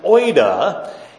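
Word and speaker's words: oida (οἶδα): oida.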